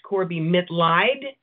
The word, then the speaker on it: Corbie Mitleid: The last name is pronounced Corbie Mitleid, okay.